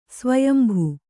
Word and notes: ಸ್ವಯಂಭು: svayambhu

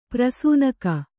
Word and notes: ಪ್ರಸೂನಕ: prasūnaka

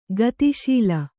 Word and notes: ಗತಿಶೀಲೆ: gati śile

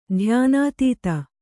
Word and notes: ಧ್ಯಾನಾತೀತ: dhyānātīta

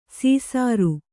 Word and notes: ಸೀಸಾರು: sīsāru

